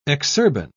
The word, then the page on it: exurban: British English